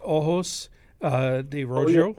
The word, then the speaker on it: Ojos de Rojo: I believe that's pronounced Ojos de Rojo.